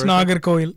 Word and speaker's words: Nagercoil: You've tried to show me Nagercoil. Is that? – Yeah, it's Nagercoil. Yeah. – Nagercoil.